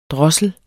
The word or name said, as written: drossel